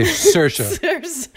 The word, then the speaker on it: Saoirse: It's just say Saoirse.